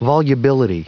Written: volubility

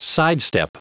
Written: sidestep

